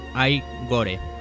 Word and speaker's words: Igor: Hablamos de Marty Feldman, una cara que no se olvida, sobre todo por su papel de Igor, aquí pronunciado Igor. Otro chiste de doble sentido que no se coge en el doblaje, pues se pronuncia como Igore. Ay de ojo y gore de cine gore.